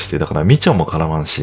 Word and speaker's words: 美酢: まあ、ビールにね、入れたりするっていうのも結構驚きなんですけども、えー、それがまあ、みちょ、えー、美しい酢って書いてみちょって読むんですけど、まあ、それがみちょですね。